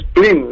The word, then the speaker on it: spleen: es sleeping, ¿no? – No, es spleen. Es spleen. S-P-L-E-E.